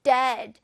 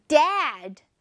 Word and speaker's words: dead: The tree is dead. 나무가 죽었어. 그쵸? 자, 따라서 말해볼게요. Dead. Dead. 여러분, 이거 발음하실 때, dad, dad, 이렇게 입을 옆으로 촥 벌리고, dad 하시면 안 돼요. 그러면 아빠가 돼요. 아빠, father 또는 dad 이렇게 말하잖아요. 그때는 d-a-d.